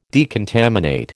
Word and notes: decontaminate: American English

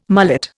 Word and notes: mullet: Indian

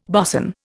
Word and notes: bosun: Indian

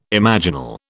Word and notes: imaginal: British English

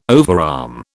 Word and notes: overarm: British English

